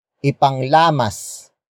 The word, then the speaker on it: ipanglamas: Word for word it's, ipanglamas ng masa ang mga kamay.